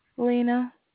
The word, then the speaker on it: Lina: Lena. – How you spell it? – L-I-N-A. – L-I-N-A? – Correct.